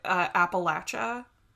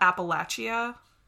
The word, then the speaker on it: Appalachia: Am I saying that right? I am from New York. – So, I think it's mostly right. I typically have heard it Appalachia. – Appalachia. – Not Appalachia. Yeah.